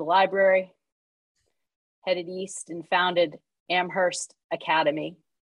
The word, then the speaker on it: Amherst: Yeah, and I do like your pronunciation. It is Amherst.